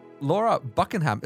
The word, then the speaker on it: Laura Buckingham: Laura Buckingham, is that how I pronounce this correctly? – That is very impressive, yes.